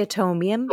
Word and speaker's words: Atomium: But her favorite one was, and I don't know if I'm pronouncing this right, the atomium or the atomium? – I think it's the atomium. Yeah, I think you're right.